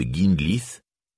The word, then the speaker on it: Ginglith: Et la première syllabe de Ginglith se prononce comme dans le mot guigne et non comme dans gingembre.